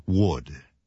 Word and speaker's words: would: Say would. Right. We spell this word W-O-U. L-D, and we pronounce it would.